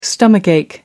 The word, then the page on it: stomach ache: American English